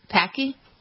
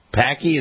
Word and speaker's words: Paqui: Is the chip a Pakui? Is that how they pronounce it? P-A-Q-U-I? – I think it's Packy. – Packy?